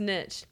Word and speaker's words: niche: Literally, niche versus niche. These are two common pronunciation variants, both of which are currently considered correct. Niche rhymes with sheesh. – Okay. – And niche rhymes with pitch.